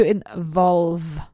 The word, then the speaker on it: involve: T-Y-R-E is British. Involucrar, to involve. No es to involve. No se labian los juntas. No se juntan los labios. To involve, right? So pronounce that V sound, this vibrating V sound.